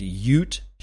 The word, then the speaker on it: Ute: It's written by a guy named Ute Schaub, or Schaub. I don't know how to pronounce it. It's Ute, U-T-E. Last name is S-C-H-E-U-B, Ute Schaub, I guess is how you pronounce it.